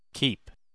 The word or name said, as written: keep